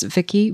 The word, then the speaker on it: Vicki: And my username on all of those sites is Vicki Voisin. That's spelled V-I-C-K-I, V-O-I-S-I-N, no spaces, no symbols.